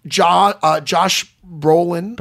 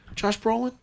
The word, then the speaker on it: Brolin: I loved Josh Brolin. – Brilliant. – Did I say that right? – Yeah. – Josh Brolin? – That is an actor.